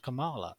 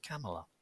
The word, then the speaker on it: Kamala: Those palate cleanser, palate cleanser tweet this is actually from Kamala Harris, and it's not it's not pronounced Kamala. It's pronounced Kamala, by the way.